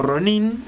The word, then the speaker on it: Ronin: Ronin, o Ronin, en su pronunciación correcta, se llama algo así como el hombre ola.